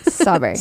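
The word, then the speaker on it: Shefali Tsabary: That's how Oprah says it. Sawberry. – Okay. The Strawberry. T is the T. – The T is silent. – I knew that wasn't right. Strawberry. silent T.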